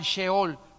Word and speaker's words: Sheol: se escribe Sheol, S-H-E-O-L, Sheol.